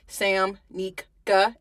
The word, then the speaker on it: Samnika: And I spelled it Samnika, S-A-M-N-I-K-A, mm-hmm.